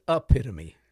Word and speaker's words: epitome: Doesn't who pronounces epitome wrong? – It's epitome.